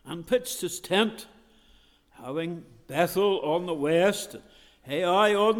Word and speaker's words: Bethel: And he removed from thence unto a mountain on the east of Bethel, and pitched his tent, having Bethel, or as we often say, Bethel. Sometimes we should emphasize the syllables, really.